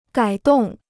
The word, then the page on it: 改动: gǎi dòng